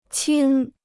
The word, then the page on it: 青: qīng